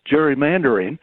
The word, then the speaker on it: gerrymandering: Gerrymandering started right here in Massachusetts with Governor Gary, G-E-R-R-Y, and they pronounce it gerrymandering.